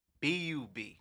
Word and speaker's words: Lightbub: LightBub, man. No L, man. BUB, man. It's Lightbub.